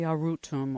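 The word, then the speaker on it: Arutam: And the spirit of the Shuar, the wisdom from the last unconquered people of the Amazon, talks about the Arutum, or the life-giving spirit of nature. I don't know if I've pronounced it properly. – You did, yes, that absolutely allows us to change ourselves.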